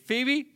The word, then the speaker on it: Phoebe: How do you say it? Phoebe? There you go.